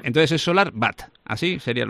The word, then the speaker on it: SolarWatt: Los alemanes ponen la W como si fuera una B. SolarWatt.